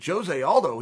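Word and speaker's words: José Aldo: He said, Jose Aldo.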